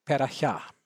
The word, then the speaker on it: Perahia: Ladies and gentlemen, we've listened to Mendelssohn's Variations Sérieuses in chopped up fashion, sorry about that, played by Murray Pariah in 1974. He was in his 20s. I once knew a Sephardic couple in Washington, D.C., a couple from Istanbul. They pronounced the pianist's name Perahia.